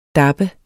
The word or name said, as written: dappe